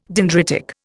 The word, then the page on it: dendritic: Indian